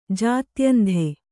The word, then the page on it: ಜಾತ್ಯಂಧೆ: jātyandhe